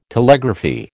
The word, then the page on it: Telegraphy: American English